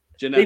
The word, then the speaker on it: Djenepo: Moussa Djenepo, is it? – Djenepo, BBC is saying they're all doubtful.